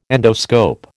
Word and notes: endoscope: American English

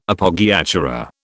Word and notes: Appoggiatura: British English

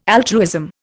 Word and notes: altruism: Indian

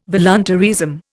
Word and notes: voluntaryism: Indian

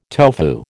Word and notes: tofu: American English

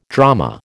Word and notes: drama: American English

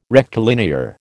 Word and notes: rectilinear: American English

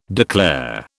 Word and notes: declare: British English

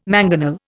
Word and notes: mangonel: Indian

